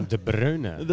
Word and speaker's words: De Bruyne: De Bruyne.